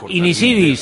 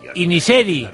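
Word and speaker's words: Inisherin: que no se llama Inisiri, sino Iniseri.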